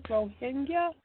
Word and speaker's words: Rohingya: Yes, this is the Rohingya. Is that how you say it? The Rohingya? Oh, Rohingya? – Yeah, that's what I've said.